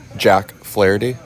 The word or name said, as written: Jack Flaherty